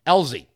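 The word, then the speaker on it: Ezell: And a little bit later after that, Representative Mike Alezi, if I'm saying that right, former pilot, Elzy.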